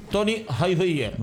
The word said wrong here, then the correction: Tommy Hilfiger: ¿Cómo se pronuncia? – Tommy Hilfiger.